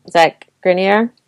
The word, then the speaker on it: Zach Grenier: Zach Grenier? Is that how you pronounce it? – Yeah, that's how you pronounce it.